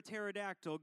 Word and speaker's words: pterodactyl: Why can't you hear a pterodactyl go to the bathroom? Because the P is silent.